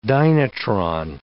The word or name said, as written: dynatron